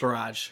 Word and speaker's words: barrage: Now, how would you pronounce that word? Barrage or Barrage? – Barrage.